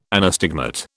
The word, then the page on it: anastigmat: British English